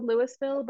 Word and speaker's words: Louisville: Yeah, actually, it's spelled Louisville, but they call it Louisville. – Louisville. – Yeah, they make the S silent.